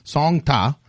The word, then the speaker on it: Song Ta: S-O-N-G space T-A. Song Ta.